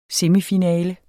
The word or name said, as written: semifinale